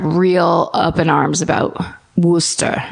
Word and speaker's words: Worcester: Also, turns out Woochester is nuts. It's Wooster. Did you know that? – I wouldn't have known it from spelling. – It's Worcester. – It's spelled Worcester. – Yeah. – And it's pronounced Wooster.